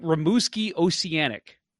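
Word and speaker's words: Rimouski Océanic: And I am I saying this right? Rimouski Oceanic. – Yeah. – Is that right? – Yep.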